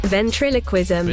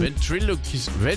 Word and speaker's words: Ventriloquism: Das ist die Ventriokism. Und da habe ich extra was vorbereitet, weil ich das selbst gar nicht aussprechen kann. Das hier. – Ventriloquism. – Ventriloquism.